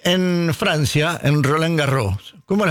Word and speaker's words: Roland Garros: Yo lo dije mal. – Sí. – Bueno, sí. – Roland Garros. – Garros, claro. La S final. – La S final a veces va, a veces no va.